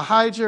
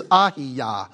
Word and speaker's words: Ahijah: Yeah, Ahijah or something like that, yeah. In Hebrew, it's Ahiyah.